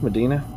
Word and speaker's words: Modena: Amort was born in Modena. Is that how you pronounce Modena? – That's what I'd say.